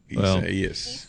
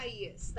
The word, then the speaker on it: Isaias: Isaias. – Well, Isaias. That's how you pronounce Isaias. The name of the system.